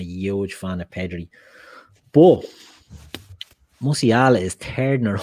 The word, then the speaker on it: Musiala: It's right, I'm going to butcher this name, Musiala at Bayern Munich. Is that right, Musiala? Yeah, Musiala. Musiala, yeah or oh, Pedri from Barcelona.